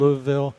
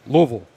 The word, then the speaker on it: Louisville: It's not Louisville, it's Louisville. – Louisville. Louisville. Louisville. Louisville. You got to pronounce it correctly.